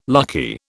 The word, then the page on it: lucky: British English